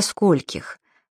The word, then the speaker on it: скольких: Языковая норма допускает и вопрос «до скольких?», причем ударение в слове «скольких» всегда падает на первый слог, оно неподвижное.